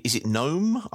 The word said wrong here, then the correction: Nombe: I don't know what he's... – Nombe, there you go.